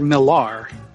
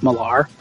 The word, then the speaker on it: Millar: Yes, that's Peter Millar, not Miller, Millar. And if you know anything about fashion, you know it's Millar. M-I-L-L-A-R, not E-R, A-R. Millar.